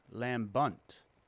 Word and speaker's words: lambent: Today's word is lambent, lambent, lambunt, lambunt. – Lambent, lambent, lambunt. – Lambunt. I'm looking at the pronunciation there. Lamb, hard lamb, bunt.